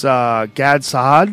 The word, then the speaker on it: Gad Saad: It's Gad Saad. Did I say it right? – Yep, G-A-D-S-A-A-D. – G-A-D-S-A-A-D on Twitter.